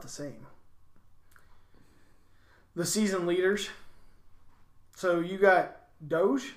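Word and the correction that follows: Doege: is that how you pronounce his last name? Your quarterback? – Daigie. – There's an O in there. – It's Daigie. – Okay, Daigie. Even though it's D-O-E, it's Doge. – It's Daigie.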